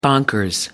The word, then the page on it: bonkers: American English